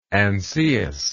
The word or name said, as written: Ancaeus